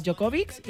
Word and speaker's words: Djokovic: Y también van a salir, lo tengo aquí apuntado, Roger Federer, Novak Djokovic y Carlos Moya.